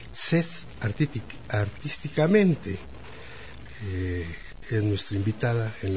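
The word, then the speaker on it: Zaz: Cés, se escribe Z-A-Z, y en francés se pronuncia Cés, que su pasión por la música viene desde muy pequeña.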